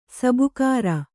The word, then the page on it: ಸಬುಕಾರ: sabukāra